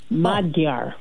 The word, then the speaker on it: Magyar: So the Magyar, the G-Y is pronounced D-Y-E, like Dia. Magyar.